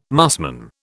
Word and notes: musmon: British English

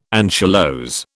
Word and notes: anchylose: British English

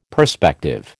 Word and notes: perspective: American English

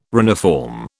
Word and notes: reniform: British English

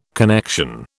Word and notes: connection: British English

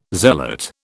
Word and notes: zealot: British English

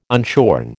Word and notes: unshorn: American English

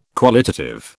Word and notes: qualitative: British English

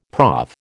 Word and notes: prof: American English

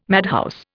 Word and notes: madhouse: Indian

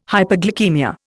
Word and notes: hyperglycaemia: Indian

